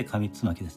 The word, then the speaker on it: 上巻: えー、古事記と書いて古子と文、上巻と書いて上つまきですね。